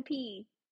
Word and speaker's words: Austin Peay: On Tuesday, they're playing Austin Peay. – Okay, so is the Y just like there for fun? – I think it's P.E. Austin P.E.